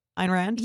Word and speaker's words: Ayn Rand: Ayn Rand? – Yeah.